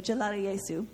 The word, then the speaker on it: Jalali Yesu: The rest of the song is in English, so Jalali Yesu is how to pronounce that part.